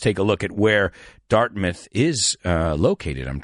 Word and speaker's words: Dartmouth: This is dart mouth. However, it's not pronounced dart mouth. It's pronounced Dartmouth. So that M-O-U-T-H stops being mouth because it's the ending of a word. It's not the word mouth.